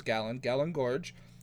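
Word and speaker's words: Galyn Görg: And I was correct on pronouncing her name. It is Galen Gorge.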